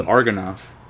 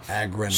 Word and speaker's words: Agronoff: Is that how you say his name? I thought it was Argonoff. – It's definitely, it's a G before R, yeah. – Agronoff.